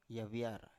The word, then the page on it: yaviar: Biak